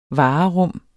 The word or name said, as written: varerum